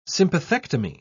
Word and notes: sympathectomy: British English

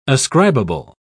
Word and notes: ascribable: British English

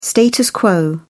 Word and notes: status quo: British English

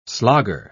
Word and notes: slogger: British English

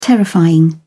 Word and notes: terrifying: British English

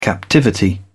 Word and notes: captivity: British English

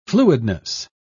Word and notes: fluidness: British English

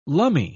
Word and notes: lummy: British English

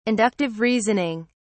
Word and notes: Inductive reasoning: British English

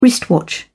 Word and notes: wristwatch: British English

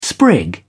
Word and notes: sprig: British English

